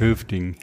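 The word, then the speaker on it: Hövding: Wie spricht man es aus, Schwedisch? Höfding. – Höfding, Helm.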